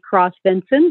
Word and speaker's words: Kross-Vinson: And my name is Molly Cross Vinson.